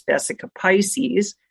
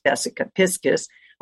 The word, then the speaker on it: Vesica Piscis: And you see the Vesica Piscis, or I used to think it was Vesica Pisces, that symbol, but I was told it's actually the correct pronunciation is Vesica Piscis.